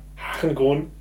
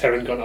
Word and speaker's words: Harengon: Ja, das ist, also die Herengon oder Harengon, aber ich würde es Herengon aussprechen, von Hare, also Hase.